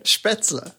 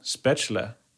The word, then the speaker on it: Spätzle: Spätzle. – Oh, there you go. – Spätzle. – Spätzle. – Spätzle.